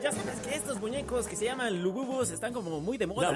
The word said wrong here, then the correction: Labubu: La Bubus. – ¿No? ¿La Bubus? – La Bubus. – ¿Lo dije mal? – Dijiste Lububus como Bubulubus.